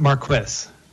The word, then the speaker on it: Marquis: Do you pronounce the S at the end of your last name? – So I usually pronounce it Marquis.